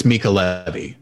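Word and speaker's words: Mica Levi: I think it's Mika Levy. That's how musicologist Wes Anthony said it.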